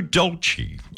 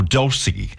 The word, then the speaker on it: Dolce: Somebody else musically who was also having a birthday is Joe Dolce, or Dulcie, depending on, you know, which side of Lake Burley Griffin you were born as to how you pronounce his surname.